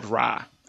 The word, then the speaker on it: Rai: Okay, it's a comic book series called Rye. It's spelled R-A-I. Sorry about the accent.